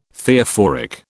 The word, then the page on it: Theophoric: British English